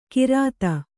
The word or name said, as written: ಘ್ರಾತ